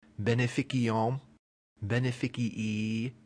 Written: beneficium

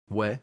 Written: ve

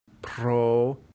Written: pro

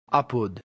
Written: apud

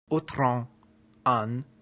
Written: utrum

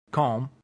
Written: cum

